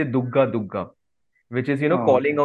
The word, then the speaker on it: Durga: So Durga Durga, but it is kind of, you know, pronounced as Dugga Dugga.